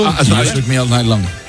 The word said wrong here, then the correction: Shook Me All Night Long: Ah, sí. Lo has dicho en francés. – O sea, has cambiado cuatro palabras de sitio. – Has engolado tanto que se te ha pasado de idioma. – Qué grande, tío. – Me cuesta el Shook Me All Night Long. – Ahí muy mucho mejor.